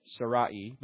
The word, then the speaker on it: Sarai: Now, that's the way, you know, he was Sarai, maybe is more close to the Hebrew from way you pronounce it. Sarai, but now it'd be Sarah.